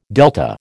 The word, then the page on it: delta: American English